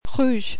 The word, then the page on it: rouge: French